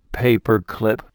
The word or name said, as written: paper clip